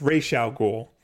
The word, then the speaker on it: Ra's al Ghul: Well, they pronounce his name wrong in the Christopher Nolan movie. – Yeah, what do they call him? – Ra's al Ghul. Oh. It's Ra's al Ghul.